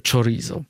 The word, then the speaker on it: chorizo: że troszkę inaczej wymawia się nazwę typowej dla Hiszpanii kiełbasy paprykowanej chorizo. W Portugalii bardziej w szy idziemy niż w czy, ale to jest bardzo podobna kiełbasa, która jest zresztą dodatkiem do wielu potraw na ciepło gotowanych.